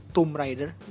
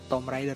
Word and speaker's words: Tomb Raider: Gue pengen, tadi gue juga baru beres nonton Tomb Raider, bacanya gitu ya, Tomb, bukan Tomb Raider ternyata. Dari dulu gue nyebutnya Tomb Raider, ternyata itu Tomb Raider.